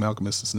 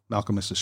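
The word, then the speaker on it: Malkmus: Sorry to bring this email down, but number one, you are mispronouncing Malcolmus's name. It's not a three-syllable word, Malcolmus. It's a two-syllable word, Malcolmus.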